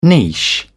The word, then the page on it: niche: British English